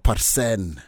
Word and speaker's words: Parsen: Is that how you say it? – Edward Parsen. – Parsen. – Parsen. – Yes, oh, Parsen. – So it's not English. – It's Maasai. It's Maasai.